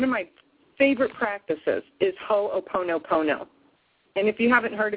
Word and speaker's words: Ho'oponopono: It's spelled H-O-O-P-O-N-O. It's exactly like it sounds. Ho'oponopono. All O's.